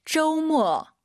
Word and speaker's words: 周末: 주말 발음을 쪼우 모가 아니라 쪼우 모어예요. 들어볼게요. – 周末.